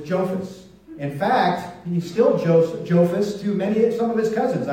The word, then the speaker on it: Jophus: J-O-P-H-U-S. Jophus. Jophus.